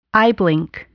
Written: eyeblink

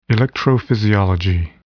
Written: electrophysiology

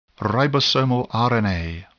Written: ribosomal RNA

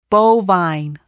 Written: bovine

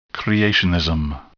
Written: creationism

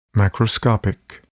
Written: macroscopic

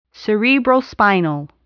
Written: cerebrospinal

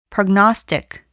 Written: prognostic

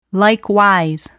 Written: likewise

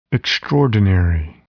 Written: extraordinary

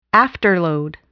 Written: afterload

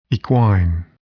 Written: equine

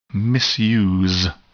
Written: misuse

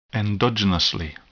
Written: endogenously

